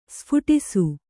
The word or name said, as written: ಸ್ಫೋಟಿಸು